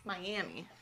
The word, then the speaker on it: Miami: I said, do you pronounce it the Florida way or Oklahoma? And she says, Florida. Isn't that weird? And I said, yes. – Okay, so. – But we know that the Florida Miami, the reason why we have an Oklahoma Miami is because of the Florida one. And it's actually Florida that mispronounces it.